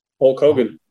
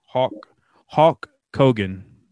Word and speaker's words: Hulk Hogan: I called him, how did I say it? Hawk Kogan, like the Hulk bird Hogan.